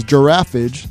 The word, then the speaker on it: Giraffage: So again, that's Giraffage. That is G-I-R-A-F-F-A-G-E.